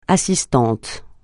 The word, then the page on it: assistante: francais